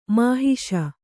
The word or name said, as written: ಮಹಿಷ